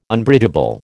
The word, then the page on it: Unbridgeable: American English